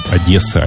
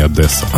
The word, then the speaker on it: Одесса: Приезжайте, только не забывайте о том, что в Одессе правильно говорить Одесса, а не Одесса.